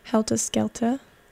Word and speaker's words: Helta Skelta: And prior to that, I played you guys Specialist Subject and Beyond the Black Stump from Helta Skelta. That's how it's spelled. I'm not just saying it with an accent.